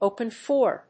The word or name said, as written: open for